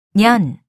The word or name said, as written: 년